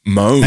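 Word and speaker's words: Môn: W-H-I-S-G-I, everyone. Anglesey Mon Distillery. Or Anglesey Mon Distillery. – Is it pronounced Mon? – M-O-N? – It's got a little accent on the top, mate. It's got a little hat on. – As well. I didn't see that accent. Moan? – Moan.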